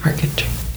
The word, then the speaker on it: marquetry: We have um, like my grandma's brother does like marquetry type stuff or market. I said that wrong. No, market, marquetry what what is that?